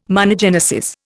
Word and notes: monogenesis: Indian

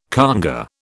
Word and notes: congÂ: British English

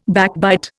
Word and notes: backbite: Indian

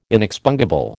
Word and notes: inexpungible: American English

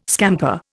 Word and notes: scamper: Indian

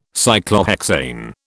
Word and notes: Cyclohexane: British English

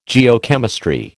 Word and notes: Geochemistry: American English

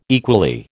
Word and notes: equally: American English

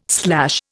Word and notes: slash: Indian

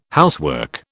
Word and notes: housework: British English

